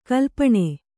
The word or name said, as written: ಕಲ್ಪನೆ